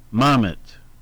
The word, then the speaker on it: Mamet: Playwright David Mamet is how they put the emphasis on the first syllable. The emphasis is on the first syllable. Mamet.